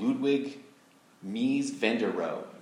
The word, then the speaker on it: Mies van der Rohe: And so a famous architect, this guy, I'm not even sure if I know how to say his name, Ludwig Mies van der Rohe.